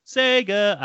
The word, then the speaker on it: Sega: The argument is, of course, is that going back to the retro days when they went Sega at the literal start of every single game, that's how it's said.